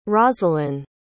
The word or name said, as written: rozalin